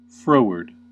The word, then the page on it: froward: American English